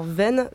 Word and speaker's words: Veynes: Comment ça s'écrit ça, Vennes ? – Alors, Vennes, V-E-Y-N-E-S. – Ah oui, Vennes, d'accord.